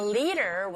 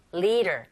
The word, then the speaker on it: leader: Leader. 자, 디사운드 강모음, 양모음 사이 껴있어요? 네. 드가 아닌 르에 가깝게 들려서 leader.